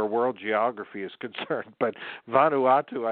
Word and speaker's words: Vanuatu: But anyway, Vanuatu, that is, everybody repeat for me, Vanuatu. Took me a year to say it properly because I kept saying Vanuatu. And it's Vanuatu.